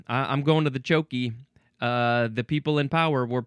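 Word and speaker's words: tjoekie: Well, it does say at the very end, it shows the word chokey, C-H-O-K-E-Y, so maybe that's how it's pronounced, but it is spelled T-J-O-E-K-I-E. I'm just gonna say chokey.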